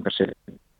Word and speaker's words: Perseverance: que se llama Perseverance, lo pronuncian Perseverance.